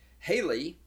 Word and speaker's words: Haleigh: Haley, it's spelled H-A-L-E-I-G-H. – McGill. Oh, for fuck's sake, really? McGill. – McGill. M-C-G-I-L-L.